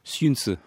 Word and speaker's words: Xunzi: Xunzi. – Xunzi.